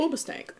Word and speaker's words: Hoobastank: Hoobastank.